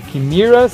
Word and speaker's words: Chimaira: Tô falando Chimera, se escreve Chimaira, banda que fundia groove metal, industrial, no metal.